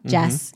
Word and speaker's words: jazz: Por ejemplo, jazz lo pronunciamos con una S, ¿no? Jazz.